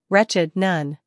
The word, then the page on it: wretched: ˈretʃɪd